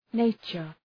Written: nature

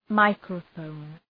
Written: microphone

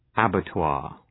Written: abattoir

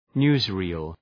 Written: newsreel

